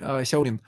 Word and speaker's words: Сяолин: У нее сложное китайское имя, как вы его произносите? – Сяолин? – Вот, да, Сяолин.